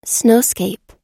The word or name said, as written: snowscape